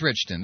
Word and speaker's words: Richton: It is Richton.